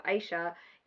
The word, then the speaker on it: Ayse: My name's Aisha. It is spelt A-Y-S-E, because, it's Turkish, so obviously it's not normal.